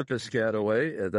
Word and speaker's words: Piscataway: Wow, I've been to Piscataway. That's the way they pronounce it, by the way.